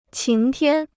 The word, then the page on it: 晴天: qíng tiān